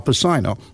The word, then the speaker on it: Pacino: So I'm standing there, and there's a scene with Al Pacino, this actor named Al Pacino. That's how they were pronouncing it. It's Pacino, of course, obviously. And it's the scene where the Godfather is telling Richard Castellano, and I forget the other one.